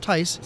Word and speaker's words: Theis: It's pronounced Theis. It's a little German.